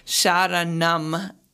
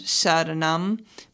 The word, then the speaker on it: Sharanam: which is Sharanam, and a lot of the lowercase a's are pronounced with an a sound, Sharanam, but, you know, not a native Sanskrit or speaker.